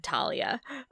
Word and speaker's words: Thalia: What if I was just a jerk and said Talia? – That's also possible. – Honestly, though, that's more Greek pronunciation. There is ditch the H entirely. – Yeah, have the sort of aspirated T sound.